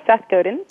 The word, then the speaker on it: Godin: Yes, Seth Godin.